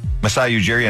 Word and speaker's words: Masai Ujiri: You lost, how do you say his name? – Masai Ujiri, and then they lost Arturas Karnišovas.